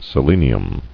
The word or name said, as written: Selenium